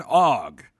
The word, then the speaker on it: ague: A-G-U-E. – So argue without the R.